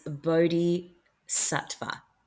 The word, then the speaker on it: bodhisattva: And sorry, I've just pronounced that completely wrong. It's bodhisattva.